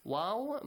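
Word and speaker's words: واو: Waw must be pronounced like wa, wa, wa, not va, like V in English. It's not V in English, it's wa.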